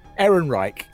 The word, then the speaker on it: Ehrenreich: Ehrenreich.